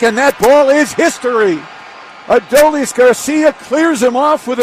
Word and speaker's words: Adolis: Adolis, I pronounce this guy's name wrong all the time. It's Adolis, right? – Yes.